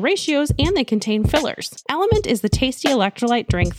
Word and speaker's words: LMNT: Today's podcast is brought to you by Element. That's spelled L-M-N-T.